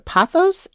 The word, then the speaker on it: Pothos: Patrick writes, this is a photo of me at grandma's house, the late 1990s, with her, okay, so am I pronouncing this right? Pothos in the corner? – Yes.